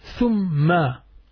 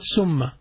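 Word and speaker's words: Thumma (ثُمَّ): Thumma. See? Thumma. And you have to pronounce it thu, not sa, not summa. Thumma. If you pronounce it summa, it will change the meaning totally.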